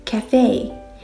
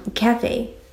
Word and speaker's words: café: 常常会有朋友会读错，那么正确的读法应该是在美式英语当中更习惯于读成 cafe，英式英语当中会习惯于读成 cafe。